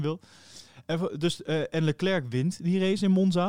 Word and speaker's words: Leclerc: Nou, vervolgens zien we daarna weer eh, de race in Singapore waar eh, het moment komt dat eh, eh, Leclerc naar binnen wordt gehaald.